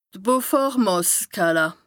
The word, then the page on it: d' Beaufort-Màsskala: Bas-Rhin; Strasbourg